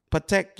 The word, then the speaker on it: Patek: Is how's it pronounced? Patek. – Patek, yeah.